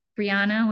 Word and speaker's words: Briana: So it's Briana Breaks. – Briana with one N. – Briana with one N, as I just spelled it, if you were listening.